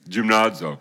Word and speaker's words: gymnazo: Gymnazo, I think, is how they pronounce it in Greek. Is that right, gymnazo? You don't know. So I'm telling you, that's how it's pronounced. Gymnazo.